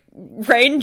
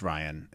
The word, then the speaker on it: Rian: Is it Rian Johnson or Rain Johnson? How the heck do you say his name? – I think it's Rian. It's just he's trying to be fancy with the way he spells it.